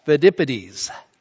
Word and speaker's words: Pheidippides: The first is the legend of Pheidippides. Say that five times. I had to say it a few times. The legend of Pheidippides, I believe, is how you pronounce his name.